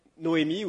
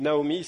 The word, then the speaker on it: Naomi: Alors, entre chapitre 1 et chapitre 2, nous avons Noémie, ou Naomi, c'est la prononciation différente, mais le même personnage, qui décide donc, pardon, qui décide de revenir à Bethléem.